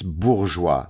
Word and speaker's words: bourgeois: It's, first of all, it's called bourgeois, right? It's bourgeois. Learn how to pronounce a French word before you pontificate like the idiot that you are.